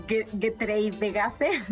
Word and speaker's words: Getreidegasse: La pronunciación es un poco complicada. Es Getreidegasse, pero es una calle súper bonita,